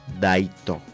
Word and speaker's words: daito: Y para poder despedirnos, nosotros vamos a pronunciar daito. – Daito. – Así como el japonés, daito. – Daito. – Así, pero es más suave, ¿no?